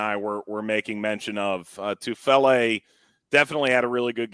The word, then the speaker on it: Tufele: I mean, obviously, Jay, and I'm not going to pronounce the name right, Tufele, I guess is how you pronounce it. – Tufele.